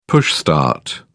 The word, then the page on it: push start: British English